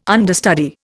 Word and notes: understudy: Indian

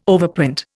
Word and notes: overprint: Indian

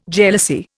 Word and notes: jealousy: Indian

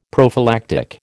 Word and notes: prophylactic: American English

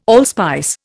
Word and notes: allspice: Indian

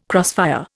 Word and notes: crossfire: Indian

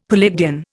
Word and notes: Palladian: Indian

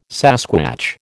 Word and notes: sasquatch: American English

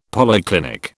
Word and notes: polyclinic: British English

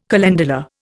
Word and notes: calendula: Indian

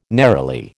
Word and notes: neroli: American English

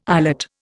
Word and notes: eyelet: Indian

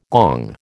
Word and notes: gong: American English

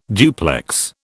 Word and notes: duplex: British English

